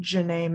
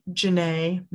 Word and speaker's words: Jenay: And then personal Instagram is Jazzy Janae May at Jazzy Janae May, M-A-Y. That's my middle name. – And Janae is spelled J-E-N-A-Y, right? – Yes.